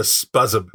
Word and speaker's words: Spuzzum: it, it's spelled S P U Z Z U M and gives me no indication of how to say it. So it's spuzz. Spuzzum is what, is what I call it.